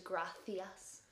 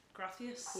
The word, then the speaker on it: gracias: So when it's a wee C, you go TH, so gracias. – Gracias.